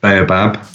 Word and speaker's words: Baobab: So then you mentioned the Baobab. Have I said that right? – Yes. No right or wrong. – I like that. So in Southern Africa, they call it Baobab.